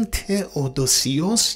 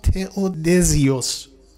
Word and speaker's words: تئودوسیوس: و نام امپراتور دیگری مطرحه که امپراتور زمانی است که اینها از خواب برخاستند، که اون تئودوسیوس یا تئودزیوس، با این دو تلفظی که باز در متون عربی و در متون سوریانی داریم، تئودوسیوس دوم، که این هم در سال 450 بوده، حالا به نقل هایی و بعضی از اختلاف ها، امپراتور روم شرقی است.